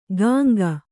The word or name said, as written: ಗಾಂಗ